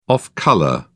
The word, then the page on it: off-colour: British English